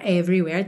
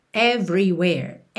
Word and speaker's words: everywhere: Sem mistério aqui, sempre bom lembrar que essas letrinhas E pintadas de cinza e não são pronunciadas e só na palavra everywhere temos duas. Então, everywhere, everywhere.